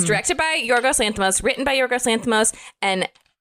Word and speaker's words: Yorgos Lanthimos: It's directed by Yorgos Lanthimos. I'm sure I'm not saying that right, but I think that's right.